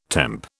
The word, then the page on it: temp: British English